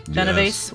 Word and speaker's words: Genovese: Am I saying that correctly? Genovese, yes.